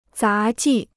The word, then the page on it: 杂技: zá jì